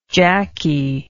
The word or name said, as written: jackey